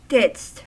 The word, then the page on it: didst: American English